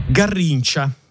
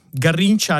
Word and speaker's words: Garrincha: Garrincia, garrincia all'italiana, ma garrincia a Rio de Janeiro con la R aspirata e il CH dolce e Garrincia in altri stati brasiliani, da qui in poi lo pronunceremo all'italiana.